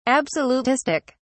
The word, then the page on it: absolutistic: British English